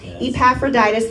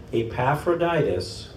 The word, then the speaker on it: Epaphroditus: Meanwhile, I thought I should send Epaphroditus. – Yes. – I said it right. – Good job. – I've been practicing, you all, and it is a hard name to say. Epaphroditus, my tongue gets in the way every time I try to say it, back to you.